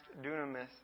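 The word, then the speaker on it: dunamis (δύναμις): This word power is pronounced dunamis in the Greek, and it means power or deed of power.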